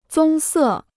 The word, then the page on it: 棕色: zōng sè